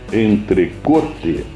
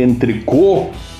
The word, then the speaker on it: entrecote: Então, eu estou falando de entrecote e não de entrecô, como tem muita gente que diz, é terrível. Tem até proprietário de açougue, restaurateiro que fala, ah, nosso entrecô é ótimo. Não existe entrecô. A pronúncia francesa é aquela que eu disse para vocês e a pronúncia em português é em português.